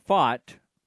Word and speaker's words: Pfaadt: Uh, Brandon, for the D backs, the starting pitcher tonight, Brandon Pfaadt, P F A A D T, let's do a silent P, uh, rides a 10.2 inning scoreless streak into NLCS Game 7.